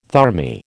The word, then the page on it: thermae: American English